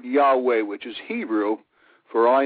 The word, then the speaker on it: Yahweh: And when YHWH is seen as it's written with the vowel points, we know the pronunciation is Yahweh. We would spell it Y-A-H-W-E-H.